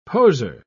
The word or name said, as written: poser